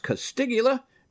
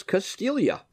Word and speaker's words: Castiglia: When you see the spelling, you would swear it should have been pronounced Castiglia, but it is Castiglia.